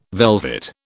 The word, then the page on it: velvet: British English